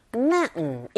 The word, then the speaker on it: mountain: 이때도 역시 T 사운드는 약하게 발음이 돼서 은 사운드, T 사운드 맞물려 있는 경우에 T 사운드가 잘안 들리거든요. 그래서 우리에게 들리는 소리는 mountain. 틀리진 않지만 원어민의 입에서 자주 등장하는, 들리게 되는 소리는 mountain라고 들리니까 여러분들도 그렇게 한번 따라해 보세요.